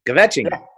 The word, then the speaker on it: kvetching: Yiddish for, you know, kvetching, you know, gossiping, kvetching.